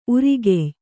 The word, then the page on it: ಊರುಗಾಯಿ: ūrugāyi